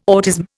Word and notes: autism: Indian

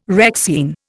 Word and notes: Rexine: Indian

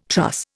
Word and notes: truss: Indian

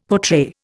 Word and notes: portray: Indian

